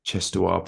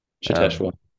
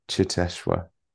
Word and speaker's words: Cheteshwar: It does help when you've got a certain Indian international in Cheteshwar Pujara in the middle order. You know, I know I pronounced that wrong. I'm very sorry. – Cheteshwar. – Cheteshwar Pujara.